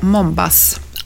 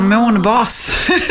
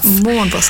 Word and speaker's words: Månbas: Mina föräldrar kommer från Tjeckien, så de uttalade det ju fel. Det ska ju vara Månbas. Alfa. – Just men det var Mombas. – Månbas Alfa, ja.